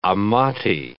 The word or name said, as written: Amati